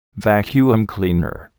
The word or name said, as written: vacuum cleaner